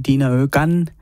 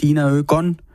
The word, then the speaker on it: Dina Ögon: Jeg kom til at sige Dina Ögon, tror jeg nok måske, og det kan åbenbart være svært med det svenske. Så selvfølgelig er det Dina Ögon, og et nummer, der lyder sådan her.